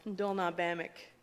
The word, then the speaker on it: Ndolna Bamik: There is a word in our language, and it's pronounced Ndolna Bamik.